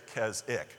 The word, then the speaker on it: Keswick: Now, it's spelled Keswick, but because it's England, they can't use all the letters, and so they just say it Keswick.